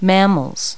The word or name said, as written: mammals